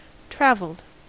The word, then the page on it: travelled: American English